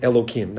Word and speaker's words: Elohim: It's referred to, God is referred to in source here in the verse 13 as Aleph Lamed Hey Yud Mem, which is pronounced with a hey, but we'll pronounce it Elohim, not to say the name of God for no reason.